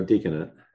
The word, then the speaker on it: diaconate: Diaconate, is that how you say it? – Or deaconate. – Deaconate, yeah.